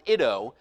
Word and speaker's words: eido: That word know in verse 12 comes from the Greek word ido, and it's spelled E-I-D-O, it's pronounced ido.